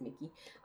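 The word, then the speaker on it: Mikki: Just some people, what's her, how do I say her name? Mika? Mickey? Mickey? How do you say her name? – Mickey? – Mickey Kendall? Yes, it is Mickey.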